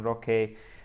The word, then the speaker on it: Roque: and that is Vitor Roque, or Roque as the Brazilians pronounce it, and we should probably too.